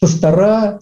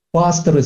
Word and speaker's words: пасторы: Значит, пастора, служителя, но это все неверно. Пасторы, служители, вот, правильное так ударение.